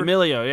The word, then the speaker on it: D'Amelio: The DeMello girl. I think, I don't know how to say it. I think it's DeMello. – Oh, Charlie D'Amelio or whatever? – D'Amelio, yeah. Something.